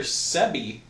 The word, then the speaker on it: Sebi: No, Dr. Sebi. It's like S-E-B-I.